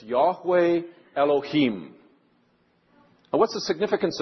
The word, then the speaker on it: Yahweh: Y-H-W-H, which we pronounce Yahweh. And 100 years ago, they would have thought was pronounced Jehovah.